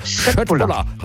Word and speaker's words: Köttbullar: Ja, Köttbullar. – Ja! Oder wenn man es ganz korrekt aussprechen, ich habe mir sagen lassen, das K wird in Schweden wie ein Sch ausgesprochen. Also wenn es Schöttbulla, hat es doch geklappt.